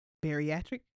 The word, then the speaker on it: bariatric: And I was accepted the second time I applied for the bariatric. Did I say it right? – Yes, you did.